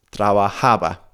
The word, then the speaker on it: trabajaba: But what I want you to bear in mind is that the emphasis falls on a slightly different syllable compared to the present tense. So it always falls on the ha. Trabajaba, trabajabas, trabajábamos, trabajaban.